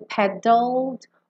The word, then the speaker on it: paddled: E como o E não vai ser pronunciado, pensa em sair do D. E já ir para o L no céu da boca. Então fica paddled, paddled, tá?